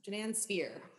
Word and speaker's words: Jeanan Sfeir: Jeanan Sfeir. – Sfeir. – Yeah, like the circle.